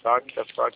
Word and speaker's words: Facta: Facta. – F-A-C-T-A. O C é mudo.